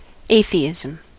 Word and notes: atheism: /ˈeɪθiɪzəm/

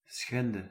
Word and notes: schenden: [sχɛn.dǝⁿ]

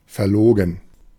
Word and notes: phony: /fəʊni/; American English

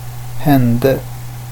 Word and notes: hände: /ˈhɛ̀nde/

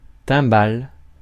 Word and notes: cup: /kʌp/

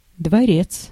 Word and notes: cour: [kuʁ]; France

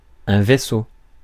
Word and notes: vessel: /ˈvɛs.əl/

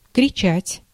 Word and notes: кричать: [krʲɪˈt͡ɕætʲ]; kritšat

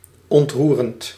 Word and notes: attendrissant: [a.tɑ̃d.ʁi.sɑ̃]; France

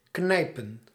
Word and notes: pincer: [pɛ̃.se]; France

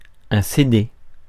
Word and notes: CD: France; [se de]

